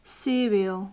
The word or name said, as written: serial